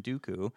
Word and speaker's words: Dooku: In the Galician and Portuguese languages, doku, pronounced exactly as in Dooku, means from the ass of the ass.